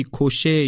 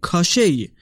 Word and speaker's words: کاشی: اول در مورد تلفظ اسم شخصیت منفی داستان، یعنی همون کاشی، من جاهای مختلفی گشتم و به تلفظهای مختلفی هم برخوردم. کاشی، کوشی یا حتی کاشی. ولی طبق اون چیزی که من متوجه شدم همین کاشی توی زبان‌های اسلاوی صحیح تر هست.